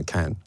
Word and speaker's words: Cannes: So Liv and I, obviously, you know, we got to Cannes, and am I saying it right now? Cannes. – Yeah, it's just you just, so we got to Cannes, whatever the fuck it is, at about 5 p.m.